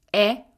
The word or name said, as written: e